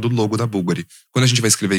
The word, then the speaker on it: Bulgari: Tem pessoas que falam vulgari, porque o U ele é usado com o V. O V, fiquem pensando nisso. O logo bulgari é com V. Mas a pronúncia é búlgari, com U, certo? – Exatamente.